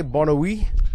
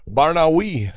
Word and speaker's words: Barnaoui: Bellator 287, Adam Piccolotti versus, how do you say, Bonawi? – Barnaoui.